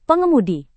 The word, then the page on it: pengemudi: Indonesian